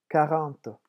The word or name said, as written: quarante